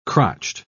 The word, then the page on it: crotched: British English